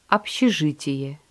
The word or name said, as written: общежитие